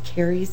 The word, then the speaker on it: Cary: My name is Carrie Zarbach. Carrie is C-A-R-Y. Zarbach is Z as in zebra, A-H-R-B-O-C-K.